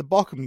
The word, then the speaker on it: Bochum: So we had the Bochum game, and thank you, Teddy, for teaching me how to pronounce Bochum.